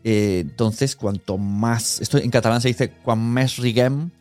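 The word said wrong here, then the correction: quan més siguem, més riurem: No, lo he dicho mal. quan mes siguem, mes riurem, ¿no?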